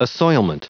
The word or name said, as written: assoilment